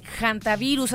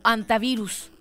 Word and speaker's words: hantavirus: Fíjate que se dio a conocer este caso del hantavirus, así se pronuncia, o hantavirus, con H.